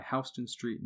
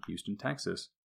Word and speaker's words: Houston: Whichever way it happened, the spelling changed, but the pronunciation never did. And that is why Houston Street, New York, is pronounced differently from Houston, Texas, despite the same spelling.